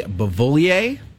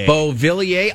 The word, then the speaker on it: Beauvillier: Again, it was Anthony Beauvillier. Terrible pronunciation. – Beauvillier.